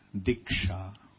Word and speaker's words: diksha: The word diksha, the Sanskrit word for initiation, is diksha. This diksha is a combination of two syllables, di and sha.